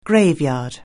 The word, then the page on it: graveyard: anglais